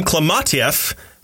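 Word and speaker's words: Klementieff: It's nice to see Pom Klimatyev with something to do.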